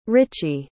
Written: Ricci

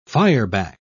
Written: fireback

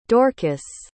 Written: Dorcas

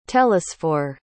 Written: télesphore

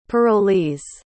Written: parolees

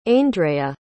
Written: aindrea

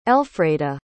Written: elfreda

